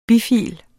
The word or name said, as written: bifil